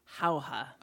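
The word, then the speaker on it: Jauja: Our first comes from Michael Locker, and he is going to recommend the film, is it pronounced Howha? – Howha. – All right, I got it right.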